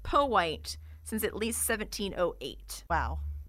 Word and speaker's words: Powhite: The debate resurfaced again in 2001 when a billboard went up on Interstate 195 near Broad Street that said Powhite is pronounced Powhite.